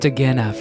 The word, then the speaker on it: Agena: Oh, it's pronounced Agena for the next Agena.